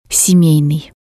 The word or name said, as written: semeinii